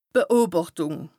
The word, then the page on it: d' Beobàchtùng: Bas-Rhin; Strasbourg